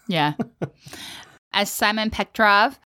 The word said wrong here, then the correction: Petrikov: Petrikov, thank you, moves towards a young Marceline in the flashback to comfort her.